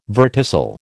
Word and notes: verticil: American English

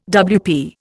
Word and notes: WP: Indian

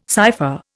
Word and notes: cipher: Indian

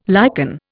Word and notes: lichen: Indian

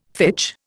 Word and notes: fitch: Indian

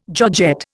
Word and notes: georgette: Indian

